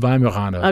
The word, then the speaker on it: Weimaraner: Our neighbors had Vimaramers, if that's how you say it. Is that how you say it? Tony's reaction is always like, Well, how do you call them? What do you call them? – Weimaraner.